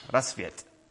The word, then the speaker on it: Rasvet: It's called Rasvet Church, Rasvet, as I heard it should be pronounced this week.